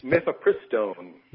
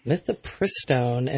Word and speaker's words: Mifepristone: Mifepristone. – Mifepristone, and it's a primer on the population control movement.